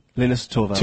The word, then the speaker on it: Linus Torvalds: Linus Torvalds.